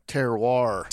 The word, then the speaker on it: terroir: Terroir.